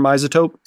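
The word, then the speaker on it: iZotope: Maybe, how do you say, Image Stereo from Ozotope. – Stereo Imager from Isotope.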